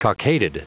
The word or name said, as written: cockaded